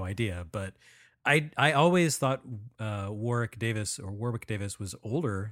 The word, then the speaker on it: Warwick: You don't pronounce the W. It's Warwick.